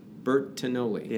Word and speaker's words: Bertagnoli: Bertagnoli.